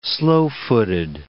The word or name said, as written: slow-footed